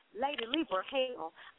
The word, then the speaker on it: Lady Libra Hell: Just go to my page, Lady Libra Hell, that's LadyLibra, one word, space, Hell, H-E-L-L, and it's pronounced Lady Libra Hell.